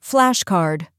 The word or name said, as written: flashcard